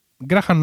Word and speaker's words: Graham: Eh, Graham Norton desde luego lo es. Ya no sé si todo el show es en la tele británica la H no se pronuncia.